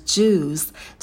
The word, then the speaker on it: juice: And fresh juice. В слове «juice» у нас долгий «у». Губы вперед не вытягиваем, а стараемся произносить этот звук и с оскалом.